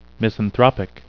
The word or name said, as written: misanthropic